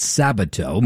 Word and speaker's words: Sabato: And I butchered Larry's name. It's Sabato.